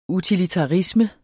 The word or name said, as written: utilitarisme